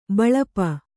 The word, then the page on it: ಬಲ್ಪು: Kannada